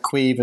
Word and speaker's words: Caimh: We should just tell our listeners that Quive is spelled C-A-I-M-H.